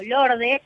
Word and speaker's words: Lorde: Se llama Lord o Lorde, se escribe Lorde como sí, Lord.